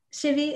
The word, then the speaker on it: Shivii: It's either way. – I'm so sorry. I've been pronouncing it Shivii or Shivii this entire time. – It's, yeah, people do either way. It's a name that's been around for so long and people flip-flop between the two.